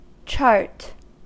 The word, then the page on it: chart: American English